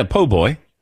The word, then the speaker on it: Po' Boy: A Po' Boy was nice too. Did I say it right? – Yeah, Po' Boy.